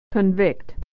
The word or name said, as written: to convict